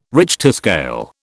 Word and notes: Richter scale: British English

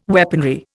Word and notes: weaponry: Indian

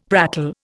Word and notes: prattle: Indian

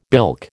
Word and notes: bilk: American English

